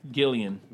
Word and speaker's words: Gillan: Yeah, Gillan.